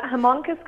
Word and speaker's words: Haemonchus: And this one particularly, now this is Haemonchus. Is it, am I pronouncing that right? – Yeah, Haemonchus contortus is the name of it.